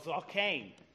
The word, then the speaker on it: zaqen: It's called zaqen, and it's spelled Z A Q E N.